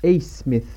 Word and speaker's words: Abmas: Max A. – Smith. – A. Smith Ok, pardon. Je dis Mais ça s'écrit Abmas, mais ça se dit A.